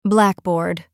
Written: blackboard

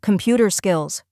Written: computer skills

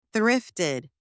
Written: thrifted

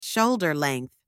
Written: shoulder length

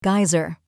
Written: geyser

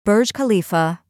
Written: Burj Khalifa

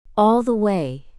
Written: all the way

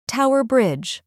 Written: Tower Bridge

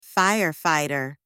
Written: firefighter

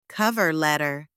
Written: cover letter

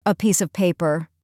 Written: a piece of paper

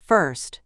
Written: first

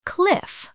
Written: cliff

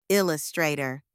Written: illustrator

